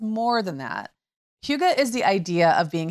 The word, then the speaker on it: hygge: That's hygge, or if you were just seeing it in print, you might think higgy, but it's actually pronounced hygge.